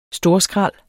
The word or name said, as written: storskrald